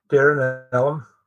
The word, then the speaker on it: Derrinallum: Is it Derrinillum? Is that how you say it? – Derrinillum, yes.